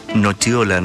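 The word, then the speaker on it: nocciola: Y se pronuncia nochiola, nos dice por acá Daniel.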